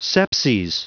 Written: sepses

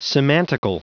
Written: semantical

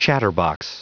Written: chatterbox

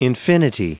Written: infinity

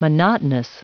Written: monotonous

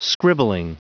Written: scribbling

